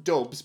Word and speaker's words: DVBBS: I'm presuming it's supposed to be pronounced Dubs, but it's spelled D-V-B-B-S.